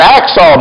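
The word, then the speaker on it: Achsah: They're trying to say Hacksaw, but they can't get Hacksaw out, but they get Aksah. And that's the name, that's how it's pronounced, the name of Caleb's daughter.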